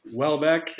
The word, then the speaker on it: Houellebecq: Yes, Houellebecq is how you pronounce it.